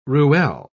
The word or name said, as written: ruelle